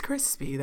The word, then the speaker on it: Chrispy: And my name is Chrispy.